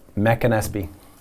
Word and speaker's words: McAnespy: Okay, you just tell me how to say it. – It's Mac and Espy.